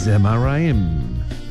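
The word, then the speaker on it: Zemaraim: 그 다음에 스마라임은 제마라임 Z로 시작을 합니다.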